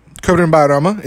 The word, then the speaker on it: Kurban Bayram: Kurban Bayram is actually the proper way to say it.